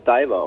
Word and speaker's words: DVR: Nee, es wird tatsächlich einfach Diver ausgesprochen, wie der Taucher sozusagen.